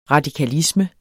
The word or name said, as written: radikalisme